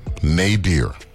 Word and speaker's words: nadir: And the sad reality is, it looks like just the disregard for life is at an absolute nadir. N-A-D-I-R, like how to pronounce nada in St. Thomas. Actual terminology is nadir with certain people.